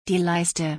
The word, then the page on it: die Leiste: German